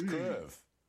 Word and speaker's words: Kyiv: Our Indo-European languages tend to be forward tongue speaking, so we don't have the vowel. And I will try and pronounce it now, and I will get it wrong, but it is basically So it's Kyiv. – It is a two-syllable word.